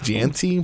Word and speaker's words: Jeanty: Yeah, it's Jeanty, but it's like it's spelled like jeans.